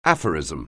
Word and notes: aphorism: anglais

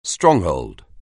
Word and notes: stronghold: anglais

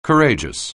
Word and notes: courageous: anglais

